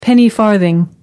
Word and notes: penny-farthing: American English

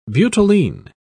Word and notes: butylene: British English

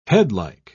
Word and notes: headlike: British English

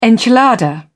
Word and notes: enchilada: British English

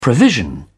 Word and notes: provision: British English

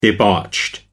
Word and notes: debauched: American English

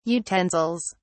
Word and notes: utensils: British English

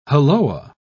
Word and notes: halloa: British English

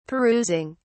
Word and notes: perusing: British English

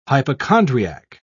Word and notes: hypochondriac: British English

